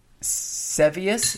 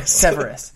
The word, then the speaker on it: Severus: So the ones who put Sevius, is that his name? Servius. I'm sorry. – Severus.